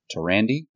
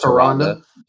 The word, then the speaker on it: Tyrande: But we also have Tyrande, I think is the pronunciation. And I call it Tyrande.